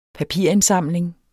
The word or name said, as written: papirindsamling